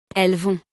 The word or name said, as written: elles vont